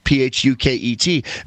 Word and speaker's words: Phuket: I'm going to, it rhymes with Ducket. So I'm going to Ducket. You want anything? Yeah. So there's this couple, this picture in this New York Times article. It says, police in Phuket, Thailand said attackers held a young Russian man in his apartment until he transferred $100,000 worth of Bitcoin to their control.